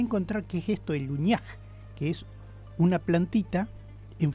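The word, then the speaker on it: Huñaij: El Uñaj, se escribe H-U-Ñ-A-I-J, Uñaj.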